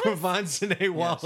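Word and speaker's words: Quvenzhané Wallis: Quvenzhané Wallace? – Quvenzhané Wallace, yes.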